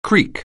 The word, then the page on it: creek: anglais